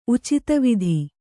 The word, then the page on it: ಉಚಿತವಿಧಿ: Kannada